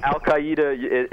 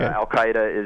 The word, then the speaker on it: Al-Qaeda: Al-Qaeda is the proper way, but okay. Al-Qaeda is the popular way.